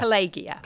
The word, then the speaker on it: Collegia: Collegia. – Collegia. – But they're pronouncing it as J, aren't they? – Yes.